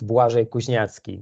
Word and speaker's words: Błażej Kuźniacki: Yes, it's Błażej Kuźniacki.